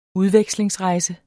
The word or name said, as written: udveksling